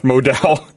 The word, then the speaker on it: Modal: Modal, I said it. I've been saying it wrong, apparently, for months. MeUndies is made from Modal. They wrote it in here. Pronounced Modal. – Like Dallas.